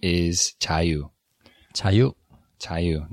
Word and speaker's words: Jaiyu: Now we chose Jaiyu because it's uh, it, actually, if I say it in English pronunciation, Jayu, right? Mm-hmm. The voiced J sound. We uh, we spell it J A I Y U, and we wanted a name that would be pronounced virtually the same in Korean and English.